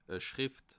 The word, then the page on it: e Schrìft: Bas-Rhin; Haguenau